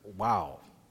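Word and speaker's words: Waw: That second portion is with the Hebrew letter wow. That's how that's pronounced. Wow.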